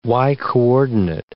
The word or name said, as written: y-coordinate